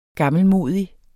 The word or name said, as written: gammelmodig